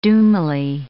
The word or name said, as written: doomily